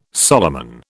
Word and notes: Solomon: British English

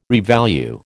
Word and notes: revalue: American English

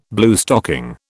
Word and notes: Bluestocking: British English

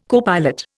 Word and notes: copilot: Indian

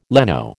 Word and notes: leno: American English

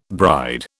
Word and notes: bride: British English

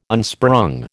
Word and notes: unsprung: American English

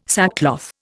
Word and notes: sackcloth: Indian

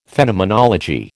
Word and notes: Phenomenology: American English

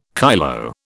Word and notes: kyloe: British English

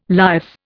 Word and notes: lias: Indian

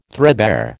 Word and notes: threadbare: American English

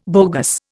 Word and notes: bogus: Indian

